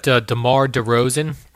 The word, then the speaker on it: DeMar DeRozan: I saw that DeMar DeRozan. Is that how you say his name, Jerry? – Yeah, you nailed it.